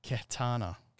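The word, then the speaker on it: Khetanna: Yeah, it's a funny name because we've heard it before in the Star Wars mythos, but it's spelt slightly different. But it's Katana.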